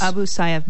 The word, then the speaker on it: Abu Sayyaf: military personnel going in to assist Filipino military personnel in their campaign in various islands in the South Philippines against Abu Sayyaf. Is that how it's pronounced? – Abu Sayyaf bandits.